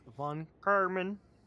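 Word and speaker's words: von Kármán: China's U-2 rover captured images in the mystery structure from around 260 feet away while navigating across von Karman crater. You have to say it like that. That's the guy who discovered it.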